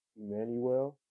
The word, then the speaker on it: Emmanuel: And my name on Facebook, if you want to find me up there, is Emmanuel, like the Bible. And for those for people that don't read the Bible, let me spell it for you. E M M A N U E L.